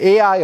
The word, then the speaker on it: Ai: The other one is called, you can either pronounce it Ai or I.